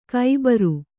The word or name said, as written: ಖಬರ